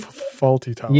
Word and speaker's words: Fawlty Towers: Fawlty Towers. – Yes. Yeah, that's it.